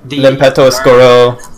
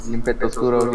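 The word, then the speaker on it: L'Impeto Oscuro: Lemilenzo Oscura. – Limpeto Oscuro. – Limpeto Oscuro, yeah.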